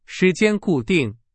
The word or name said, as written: 固定